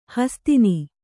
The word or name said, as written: ಹಸುತನ